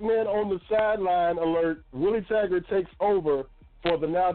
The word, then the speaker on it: Willie Taggart: It's just on what Willie Taggart could get.